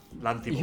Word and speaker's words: Lanthimos: Is it Lanthimos or Lanthimophos? – It's Yorgos Lanthimos.